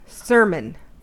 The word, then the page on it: sermon: American English